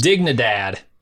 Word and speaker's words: Dignidad: I could see Fring growing up in one of these communities, maybe as an adopted or abducted child in Colonia Dignidad, or in a... – You can't be saying that right. – Dignidad? – No, man. That's a hard G. – Dig. – In Spanish. – Dignidad.